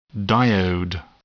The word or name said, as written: diode